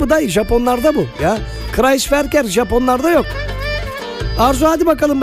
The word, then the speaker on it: Kreisverkehr: Okunuşu da Kreisverkehr.